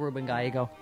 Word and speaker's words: Gallego: so a fine American like Ruben Gallego.